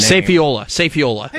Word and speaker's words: Safiola: Safiola is how you say it. Safiola. Yes, I had to say it on a 5 o'clock newscast, so I learned how to do it.